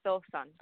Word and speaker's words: Sofan: Can you pronounce that again, sweetheart? I always think it's highly important to get people's names pronounced right for some reason. Sofan. – Sofan. – Oh, it's just the way it's spelt.